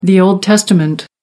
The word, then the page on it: old testament: American English